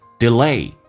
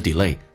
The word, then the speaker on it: delay: 来看一下 delay 这个单词，美式发音重音在后面，前面的 a 可以发成 a，当然你可以说成 delay，但是有些老外比较的 lazy 比较懒，发成 delay，好像 before 说成 before， behind 说成 behind， enough 说成 enough， delaying 拖延 what you've been dying to do。